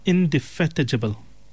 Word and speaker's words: indefatigable: It's pronounced as indefatigable. Indefatigable. It's spelled INDEFATIGABLE.